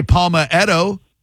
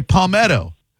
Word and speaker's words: Palmetto: You don't say Palmaghetto. You say Palmetto. Palmaghetto. – No. Palmaghetto. – No, you're wrong on this one. You don't want to add an extra syllable when you don't need to, my good man.